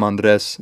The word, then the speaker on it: Andres: I'm Andres Zanelli. If you can't roll your R's, it's all good.